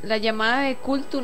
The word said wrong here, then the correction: Cthulhu: no sé cómo se dice, nunca supe. Ah, Cthulhu es.